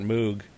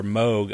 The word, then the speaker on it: Moog: their tribute to Robert Moog, or Moog, I should say, is the way you correctly pronounce it.